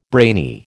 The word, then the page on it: brainy: American English